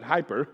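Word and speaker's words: hooper (ὑπέρ): They pronounce it hooper. We pronounce it hyper.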